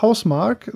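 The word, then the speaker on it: Housemarque: Ein Spiel für den PC und die PlayStation 4 von der wunderbaren finnischen Entwicklerfirma Hausmarque oder Hausmarque oder wie man auch immer das aussprechen mag. Ich sage ja gerne Hausmarque. – Ja, ich finde es auch schöner, aber ich fürchte, die heißen einfach Hausmarque, sind nur so geschrieben dann, ne? – Ja.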